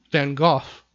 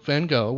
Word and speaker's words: Van Gogh: Van Gogh, or Van Gogh, whichever way you wish to pronounce it.